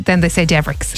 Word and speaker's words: Devereux: Your name has an X that you don't pronounce. – Unless you go to Wexford, then they say Devericks.